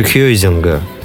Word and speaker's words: Хейзинга: И смотри, э, в общем, Хейзинга, да, тут вполне может быть он и Хейзинга, а не Хейзинга. Да, потому что он пишется через Ю. Угу. Значит, все-таки больше Хейзинга.